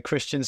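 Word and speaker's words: Kristian: So Christian spelled with a K, Christian Ultra.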